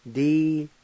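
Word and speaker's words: diliyo: Di-li-yon.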